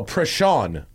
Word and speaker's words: Prashan: It's pronounced like Sean. Oh, Prashan. Oh, I got it.